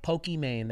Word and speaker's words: Pokimane: Pokimane. Did I say it right? – Pokimane. There you go.